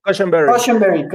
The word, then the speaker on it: Cushenberry: ritmo este Jason Berry. Cushenberry. Cushenberry, perdón, siempre lo digo mal.